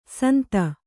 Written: ಸುಂದು

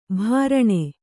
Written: ಭರಣ

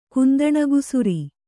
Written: ಕುಂದಣಗುಸುರಿ

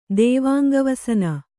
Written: ದೇವಾಂಗವಸನ

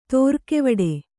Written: ತಾರಗೆವಟ್ಟೆ

